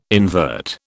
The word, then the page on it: invert: British English